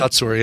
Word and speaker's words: Kotsur: Best supporting actor, is it Tony Kutsur? Kotsur? Is that how you say it? – Kutsur, yeah. Yeah, the first male deaf actor to win.